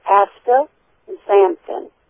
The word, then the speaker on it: astaxanthin: Well, it is astaxan, and whenever you see an X-A-N, it is pronounced zan, as in Z for zebra. So it is astaxanthin.